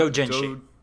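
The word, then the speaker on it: doujinshi: If Japanese manga hentai whatever you dojinshu how do you pronounce dojinshi. – Dojinshi, yeah.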